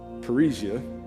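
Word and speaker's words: parrhesia: Parisia is the way you pronounce boldness in Greek,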